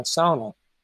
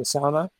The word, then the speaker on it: sauna: Um, I think mainly Finland is the origin of the sauna. They pronounce it sauna. We say sauna, but same thing.